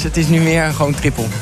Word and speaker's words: Triple: En kent u natuurlijk allemaal van de Triple It. Zo zeg ik het goed, hoor. Triple It, toch? Nee, triple gewoon. Triple? Ja, het heette vroeger Triple IT, maar we hebben het veranderd in triple.